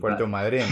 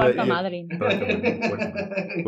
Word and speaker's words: Puerto Madryn: I can't pronounce that for the life of me. – Puerto Madryn, right? You're Puerto Madryn.